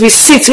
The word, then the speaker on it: receipt: So to avoid this, the particular pronunciation is receipt, whereby the P is silent.